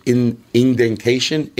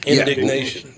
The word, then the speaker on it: indignation: He mastered fake indentation. Hey, what's it when you're a fan? What's that word? – Indignation.